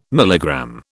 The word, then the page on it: milligram: British English